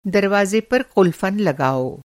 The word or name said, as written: क़ुलफ़न